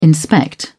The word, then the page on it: inspect: British English